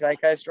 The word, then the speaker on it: Zeitgeist: Ooh, so surprisingly, she has some pop zeitgeist. Did I say it right? – Nope. Zeitgeist. – Zeitgeist.